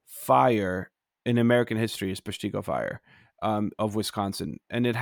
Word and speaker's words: Peshtigo: Am I saying that right? Peshtigo? – Peshtigo's Wisconsin, yeah.